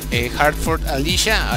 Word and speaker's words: Heart Forth, Alicia: Síganle en la pista a este gran juego, Hertford Alicia. Al fin ya lo dije bien.